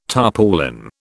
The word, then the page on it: tarpaulin: British English